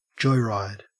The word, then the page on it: joyride: Australian English